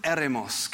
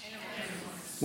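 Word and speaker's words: eremos (ἔρημος): Now, the word for wilderness in the Greek, it is the term eremos. Can you say eremos? eremos.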